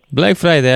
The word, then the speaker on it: Black Friday: Am să spun uh, uh un istoric al uh, Black Friday, Friday sau cum se pronunță. – Black Friday, așa se pronunță, uh, exact așa.